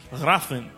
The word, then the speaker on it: Hrathen: H-R-A-T-H-E-N. Raffen.